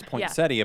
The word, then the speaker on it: poinsettia: It's like, it's like correctly pronouncing poinsettia, and people are like, "No, you mean a poinsettia?" Yeah. Well, like, no, it's poinsettia, but no one says it that way.